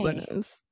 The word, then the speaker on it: Lanez: It does not look like it's pronounced Lanez. – It does look like it's pronounced okay. Lanez.